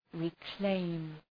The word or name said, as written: reclaim